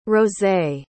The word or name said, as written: rosé